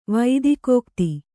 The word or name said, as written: ವೈದಿಕೋಕ್ತಿ